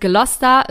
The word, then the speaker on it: Gloucester: Ähm, genau, also sie heißt Gloucester, wird aber eher Gloucester geschrieben, also nicht wundern an dieser Stelle.